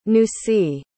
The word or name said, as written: nusi